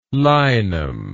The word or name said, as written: linum